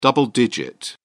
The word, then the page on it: double digit: British English